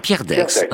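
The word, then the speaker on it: Pierre Daix: Comment prononce-t-on, Pierre Dex ou Pierre D, le rédacteur en chef? – Pierre Dex, hein, rédacteur en chef.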